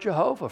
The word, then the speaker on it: Yahweh: We're not sure how they pronounce it, because just consonants don't give us a pronunciation. And so we believe that it was pronounced Yahweh, but some people pronounce it Jehovah.